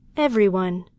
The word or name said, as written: everyone